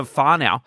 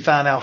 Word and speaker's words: whānau: And you said to me, I was I was now part of the the, the whānau. Um, did I say that right? – Yep, you're part of the HP whānau for sure.